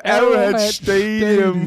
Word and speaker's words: Arrowhead Stadium: Die Cincinnati Bengals schlagen die Kansas City Chiefs im Arrowhead Stadium bei 100 Stadium. ich Im, Im Arrowhead Stadium.